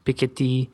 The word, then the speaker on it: Piketty: Have you guys heard of this guy, Thomas Piketty? – Piketty.